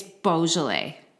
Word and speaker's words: Beaujolais: Because I always want to say Beaujolais. – But it's Beaujolais. – Which, so I took French. – I took a lot of French. – Growing up. – Conjugate your verbs. – And we definitely should have known the appropriate pronunciation. However, we both were saying Beaujolais. Like it's bougie or boozy. – Bougie.